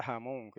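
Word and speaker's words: jamón (המון): Y suena idéntico a la palabra española jamón. Solo que la palabra española jamón se escribe con J.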